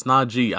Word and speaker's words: Najee: What Najee was like, it's Najee. I don't know why people keep mispronouncing it wrong. It's a double E. I'm gonna say it right now, to clear the room. It's Najee.